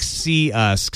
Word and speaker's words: Cius: How do you pronounce that? – CIUS. – Oh, like see us, because it's meant for video conferencing.